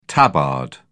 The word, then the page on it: tabard: British English